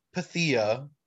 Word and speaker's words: Pythia: Anyways, next up, Pythia.